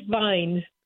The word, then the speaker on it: Vijn: My last name is Vijn. – Vijn, but it has a J in it, right? – Yes, it's a Dutch name.